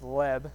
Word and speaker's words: leb: In the Hebrew, we see the word, it's probably pronounced leb, spelled L-E-B.